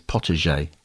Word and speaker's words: potager: i'm so glad you corrected me from my my mispronunciation there Pot- i said potager didn't i and it's potager well done. Yeah. that sounds like a French derivative, doesn't it?